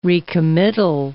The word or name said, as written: recommittal